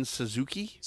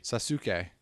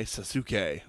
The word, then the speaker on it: Sasuke: Is it Itachi and Sasuke? – Sasuke. – Okay, Sasuke. Oh, I'm sorry. – Just telling you how it's pronounced.